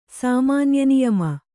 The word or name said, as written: ಸಾಮಾನ್ಯನಿಯಮ